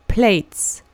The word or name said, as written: plates